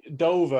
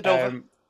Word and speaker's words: Dovah: The Dover are also known as what animal in Skyrim? I think that's how you say it. – Yeah, yeah. – D-O-V-A-H. – Yeah, the Dover.